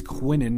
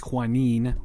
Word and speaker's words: Quinnen: It's Quinnen, not Quinine, whatever, whatever it was.